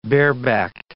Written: barebacked

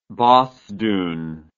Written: bosthoon

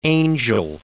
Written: Angell

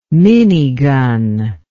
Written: minigun

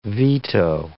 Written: veto

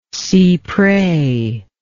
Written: cypres

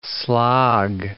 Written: slog

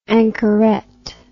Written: anchorette

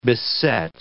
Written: beset

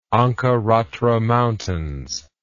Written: ankaratra mountains

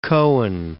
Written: Cohen

